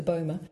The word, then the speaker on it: Boma: Its name is the Bomber, but it's spelt B-O-M-A, and I've always pronounced it the Bomber.